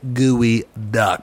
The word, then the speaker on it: geoduck: So if you're on your phone, go ahead and look up a geoduck or gooey duck, but it's spelled geoduck, and you'll see why it was like, let's just say it was low-hanging fruit.